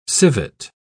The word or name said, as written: civet